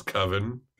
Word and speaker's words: Coven: And he's arguing about the pronunciation of Coven. He says it's Coven. Mm-hmm. And Mark says, no, that sounds like oven. That's not fucking right, man. It's Coven. The movie's fucking called Coven.